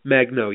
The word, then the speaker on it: Magno: Magno, yep.